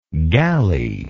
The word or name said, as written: galleylike